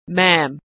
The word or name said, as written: maam